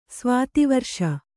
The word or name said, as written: ಸ್ವಾತಿವರ್ಷ